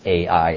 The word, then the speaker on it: Ai: That's how you pronounce Ai in Hebrew. Just Ai, like your eye that you see with.